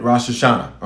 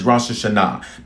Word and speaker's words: Rosh Hashanah: If you want to say it, a little country on it, Rosh Hashanah. All right. But the appropriate pronunciation is Rosh Hashanah.